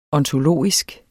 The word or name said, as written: ontologisk